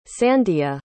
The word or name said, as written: sandia